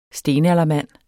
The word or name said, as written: stenaldermand